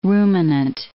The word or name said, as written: ruminant